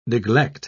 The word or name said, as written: neglect